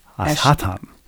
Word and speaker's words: Ashton: Now I am worried every time I pronounce A-S-H-T-O-N. It's actually pronounced Ashton.